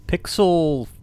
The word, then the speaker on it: Piczle: The Pixel, which is spelled P-I-C-Z-L-E, is